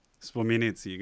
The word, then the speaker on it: Spomenici: aber ich würde mal sagen äh, Spomenic. Okay. Oder Spomenici. – Spomenici, genau.